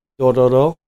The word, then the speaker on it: Dororo: Dororo.